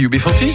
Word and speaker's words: UB40: UB40. – Ben c'est... – Ah ben c'est ça, c'est UB40, c'est ça? – Ah oui.